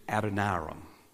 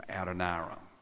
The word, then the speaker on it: Adoniram: Now, some of you have stumbled over the correct pronunciation of the name of Pastor Jeff's youngest son. His name is Adoniram.